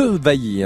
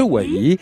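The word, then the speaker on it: Dewailly: Festival donc à Amiens au cloître de Vailly, hein. c'est comme ça qu'on prononce. – de Wailly.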